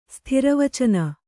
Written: ಸ್ಥಿರವಚನ

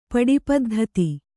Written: ಪಡಿಪದ್ಧತಿ

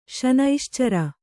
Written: ಶನೈಶ್ಚರ

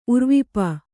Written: ಉರ್ವಿಪ

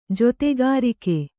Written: ಜೊತೆಗಾರಿಕೆ

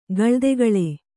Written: ಖಲೋತ್ಕಾಲಿ

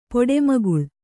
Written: ಪೊಡೆಮಗುಳ್

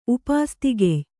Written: ಉಪಾಸ್ತಿಗೆಯ್

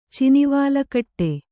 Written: ಚಿನಿವಾಲಕಟ್ಟೆ

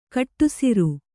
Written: ಕಡುಸಾರೆ

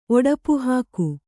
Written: ಒಡಪುಹಾಕು

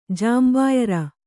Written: ಜಾಂಬಾಯರ

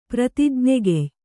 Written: ಪ್ರತಿಜ್ಞೆಗೆಯ್

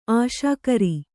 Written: ಆಸೆಕಾರ

